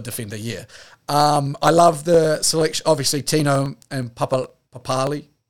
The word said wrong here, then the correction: Papali'i: Wait, how do they actually pronounce it now, is it? – Papali'i. – Papali'i, sorry.